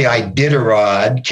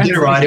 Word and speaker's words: Iditarod: You know, I don't think any adventure was the greatest, but when I did the Iditarod, Carrie, that's the way we pronounced it up there. – That's the last time. Apologies, my friend. – Yeah, Iditarod.